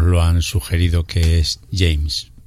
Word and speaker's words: James: Muy bien traído, además, porque debo de reconocer que en pasados programas le habíamos intentado españolizar el apellido al tal José James, pero además, como bien has pronunciado tú y alguna seguidora del programa... – Pronuncié también la semana pasada. – Sí, sí, pero nosotros nos empeñamos un poco en ese James, dejándonos llevar ahí por otros James de esta vida.